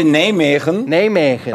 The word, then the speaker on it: Nijmegen: Habe ich e das richtig ausgesprochen? NEC? – NEC Nijmegen.